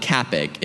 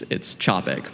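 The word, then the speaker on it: Čapek: It's called a charon. It's a diacritical mark that tells you to pronounce that first letter like the CH in chocolate. So it's not Capic, it's Chopek.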